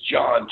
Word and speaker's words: Jon: But my website is John, J-O-N. Hyphen, not to be confused with the underscore, Anderson, A-N-D-E-R-S-E-N.